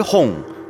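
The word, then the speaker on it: 홍 (洪): 넓을 홍, 홍? – 오늘의 단어, 천자문의 럭키 세븐이죠.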